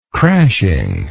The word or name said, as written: crashingly